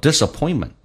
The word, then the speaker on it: disappointment: 所以后面你看像红色字体轻轻的微弱的点了一下 whenever I face disappointment 在美式发音当中那个 T 是不发音，因为后面是一个辅音，两个辅音碰在一起就用发后面一个，叫做 disappointment。